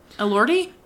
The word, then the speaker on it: Elordi: Elordi? – Elordi, thank you.